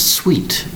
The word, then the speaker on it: suite: Today's word is sweet. Sweet. One syllable for the word sweet, spelled S-U-I-T-E. That's right, S-U-I-T-E.